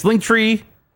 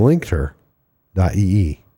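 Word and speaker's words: linktr.ee: Yes, linktree / lost joystick. – No, it's linkter.ee / lost joystick. – I'm so sorry. – It's the stress on the tur.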